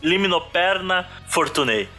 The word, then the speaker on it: Limnoperna fortunei: Liminoperma. Eu tá... É então é, é com N. Errou. Errou feio, errou feio, errou rude. – Liminoperna Fortunei.